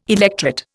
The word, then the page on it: electret: Indian